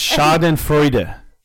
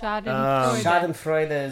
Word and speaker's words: Schadenfreude: it's pronounced Schadenfreude. – That is correct. – Schadenfreude is the word.